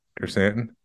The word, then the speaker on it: Krrsantan: hey, you said it right. – Krrsantan.